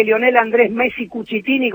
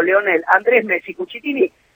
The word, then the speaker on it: Lionel Andrés Messi Cuccittini: significa, no significa que Lionel Andrés Messi Cuccittini, como hay que pronunciarlo, Lionel Andrés Messi Cuccittini, se case en el casino.